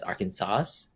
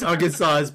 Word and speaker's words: Arkansas: And so, but in French, you apparently don't pronounce the S at the end of words, which is why you don't pronounce the S at the end of Arkansas and Illinois.